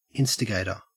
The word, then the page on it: instigator: Australian English